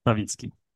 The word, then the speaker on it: Stawicki: Stawicki.